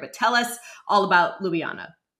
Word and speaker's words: Ljubljana: Ljubljana, Slovenia. Say it again. – Ljubljana.